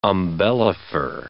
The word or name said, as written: umbellifer